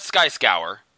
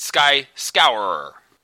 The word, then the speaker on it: Sky Scourer: S-C-O-U-R. – Scourer. That's so lame. – Scour. – Okay. – So it's not Sky Scour. It's Sky Scourer. – Doesn't look like that. It only looks like it has 2 syllables, but when you say it, it sounds like it has 50.